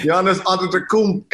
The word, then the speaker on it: Antetokounmpo: Giannis Antetokounmpo, and it's Bo, not Ho. People don't understand that it's Bo, not Ho.